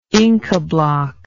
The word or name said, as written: incabloc